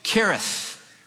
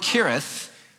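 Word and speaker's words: Cherith: Kereth, which is east of the Jordan." Now notice in the Old Testament, anytime you read a CH, it's pronounced with a K. The brook Kereth, which is east of the Jordan.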